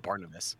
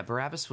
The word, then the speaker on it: Barabbas: Is it Barabbas? Is that how you say his name? I always forget to have it. Not Barnabas. – I think Barabbas was, yeah, Barabbas was the one that the criminal that they let go place of Jesus.